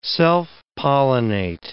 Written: self-pollinate

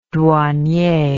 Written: douanier

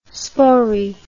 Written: spory

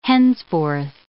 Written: henceforth

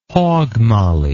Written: hogmolly

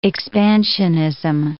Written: expansionism

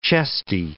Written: chesty

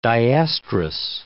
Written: diestrous

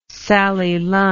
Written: Sally Lunn

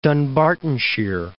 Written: Dunbartonshire

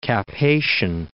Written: Cap Haitien

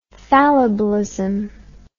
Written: fallibilism

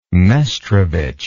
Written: mestrovic

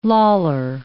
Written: loller

